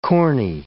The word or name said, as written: corny